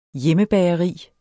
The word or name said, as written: hjemmebageri